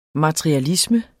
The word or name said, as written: MATERIALISME